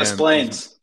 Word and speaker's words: Des Plaines: I'm pretty sure I've said that right. 6:30 p.m. – Des Plaines. – Des Plaines. Is it really Des Plaines, or are you pulling my leg now? – No, that's what we call it.